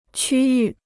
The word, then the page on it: 区域: qū yù